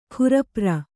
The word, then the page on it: ಕೂರ್ಪರ: kūrpara